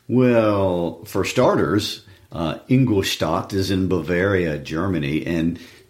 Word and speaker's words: Ingolstadt: We'll talk about him a lot next week because there's things to say, but we need to rush off to Ingolstadt. Did I say that right? – You did. – Ingolstadt.